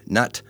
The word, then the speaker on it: Nhat: Uh, if you're interested, looking him up, it's spelled T H I C H. Middle name Nut, N H A T. Last name Han, H A N H.